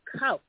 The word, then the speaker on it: Kowch: So you look at K-O-W-C-H, it's couch.